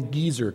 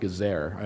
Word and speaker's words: Gezer: It's called the Geezer calendar. That's not the calendar your great-grandfather used. That's not what the Geezer calendar... I think it's actually pronounced Gezer. I'm not sure.